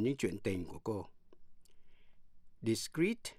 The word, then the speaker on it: discrete: Discrete, D-I-S-C-R-E-E-T là giữ kín. Và discrete, D-I-S-C-R-E-T-E là rời rạc. Phân tách. Phát âm như nhau, discrete.